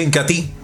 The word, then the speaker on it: Incatea: Se escribe Incatea, pero se pronuncia Incatí.